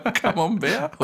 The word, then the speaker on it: Camembert: Camembert. – Meinst du Camembert? – Camembert, Untersorte Monstera.